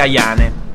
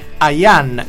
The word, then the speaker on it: Ayane: Sarà che coi problemi linguistici la cantante convive, visto che tutti sbagliano a pronunciare il suo cognome, incluso te, Ayane e non Ayane.